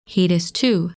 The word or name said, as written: Hoedus II